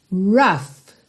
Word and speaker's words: rough: When times get rough. E atenção à pronúncia dessa palavra rough, ok? A escrita é R-O-U-G-H, mas esse OU vai ter um som de A e o GH vai ter som de F.